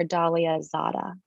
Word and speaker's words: Dalia Zada: and that's underscore Dalia Zada.